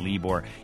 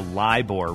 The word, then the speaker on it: LIBOR: John, you rock, says Anne, but you mispronounced the LIBOR rate. I was saying LIBOR. It's the LIBOR rate.